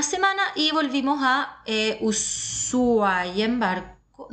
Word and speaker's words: Ushuaia: Eh, Ushuaia, que se escribe así, Allá, estando en Ushuaia, me enteré que se pronuncia Ushuaia, no Ushuaia, ¿vale?